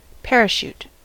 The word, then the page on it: parachute: American English